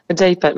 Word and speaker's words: J-PAL: Vous êtes chercheuse en économie rattachée au laboratoire J-PAL. C'est comme ça qu'on prononce ? – J-PAL, oui.